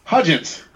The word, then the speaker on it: Hudgens: Hudgens.